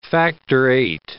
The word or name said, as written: factor VIII